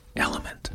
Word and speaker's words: LMNT: But Element is spelled in one of those cool ways that makes us 40-somethings feel young and hip when we figure out how to say it correctly, finally. It's the letters L-M-N-T. Element.